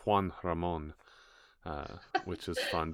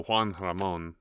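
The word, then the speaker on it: Juan Ramón: It's Juan Ramon, which is fun because I love it. – It rhymes with jamón, ham. So Juan Ramon, just like Sam I Am, rhymes with ham, Juan Ramon.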